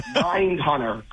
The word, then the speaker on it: Mindhunter: The two-horse Mindhunter, currently going off 12-1. That's the pick today. – Two-horse, what was it? Nine-hunter? How do you say it? – Mindhunter, like the fantastic Netflix show.